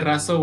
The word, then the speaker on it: Razo: Um, for eh, also for those of you that want to check out my YouTube channel, it's called uh, Jose Razo, which is just my name.